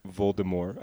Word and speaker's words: Voldemort: Or Voldemort, I believe is how it's actually pronounced.